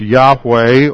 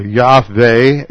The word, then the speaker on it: Yahweh: And it is sometimes pronounced Yahweh or Yahweh.